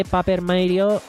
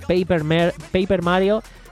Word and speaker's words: Paper Mario: Estamos hablando de un remake de Paper Mario. Hostia, qué mal lo he pronunciado. Paper Mario y la Puerta Milenaria.